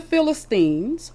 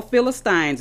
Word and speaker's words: Philistines: After the Philistines, or Philistines, it doesn't matter, both pronunciations are correct.